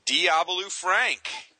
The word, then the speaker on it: Diabolu Frank: Oh, God, you actually got it right. Oh, man. See, I was looking forward to sound like Doobie-Doo Frank something bizarro, but you actually pronounced it correctly this time.